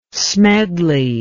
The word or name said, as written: smedley